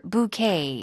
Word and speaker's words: bouquet: 꽃다발이요. 스펠링이 B-O-U-Q-U-E-T-S인데 bouquet. Bouquet. 뭐 우리가 흔히 비슷한 어떤 어감으로 읽는 불어 단어들이 debut라든가 yes. 네. – debut라든가 뭐 buffet. – Yeah. – buffet, ballet, valet 이런 것처럼 뒤에다 강세 주는 단어들이죠.